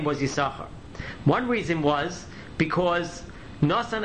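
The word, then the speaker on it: Yisachar (יששכר): And since the second sin was a negative thing, that Yaakov was exchanged for Dudoyim, therefore we don't pronounce the second sin and we call his name Yisachar instead of Yisachar.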